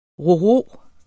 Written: ro-ro

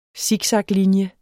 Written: siksaklinje

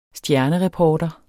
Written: stjernereporter

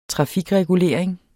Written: trafikregulering

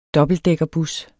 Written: dobbeltdækkerbus

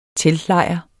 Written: teltlejr

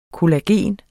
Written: kollagen

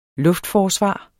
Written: luftforsvar

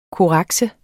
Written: korrekse

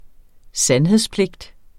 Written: sandhedspligt

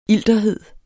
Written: ilterhed

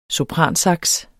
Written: sopransax

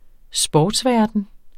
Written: sportsverden

